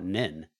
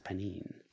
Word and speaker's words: Pnin: Spelled P-N-I-N. – Speaking of, I've been calling it Nin, but it's pronounced Panin. – Panin. P-N-I-N.